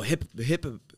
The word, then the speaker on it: Hippowdon: Aerodactyl, and then Hippodon. – Oh, Hippowdon.